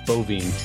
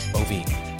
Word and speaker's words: Boivin: Is that the right way to pronounce it? Bovine. Bovine. Yeah, I saw it on, on Facebook and then I still said it wrong.